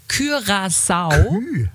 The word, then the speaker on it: Curaçao: Also richtig wäre Curaçao. Und hier bitte eure herrlichen Vorschläge. – Curaçao.